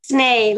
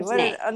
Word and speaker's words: Snay: Snay, like Snay. – Yeah, you told me Snee. – Snay.